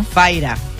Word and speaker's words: Fyra: Se llama Faira con Y. ¿Cómo se pronuncia, esto? – Faira. – Faira. – Faira. F-Y-R-A.